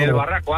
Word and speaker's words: El Barraco: Desde el Barraco, Ávila.